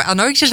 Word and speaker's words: Anoixis: En bij me aan tafel zit Stichting Anoixis. Zeg ik het goed? – Anoixis had je goed.